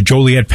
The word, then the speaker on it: Joliet: And there was a major story in the Chicago area at the Joliet Penitentiary. Did I pronounce it correctly? – Yeah.